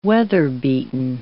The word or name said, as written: weather-beaten